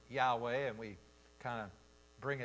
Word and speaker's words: Yahweh: And they came up with a pronunciation. And the pronunciation for I am is Yahweh in the Hebrew.